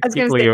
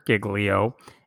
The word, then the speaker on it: Giglio: We're going to talk about, I'm pretty sure his name is pronounced Pastor Louis Giglio. Many people are like Giglio. No, I think Giglio is how he pronounces it.